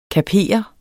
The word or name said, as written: kaper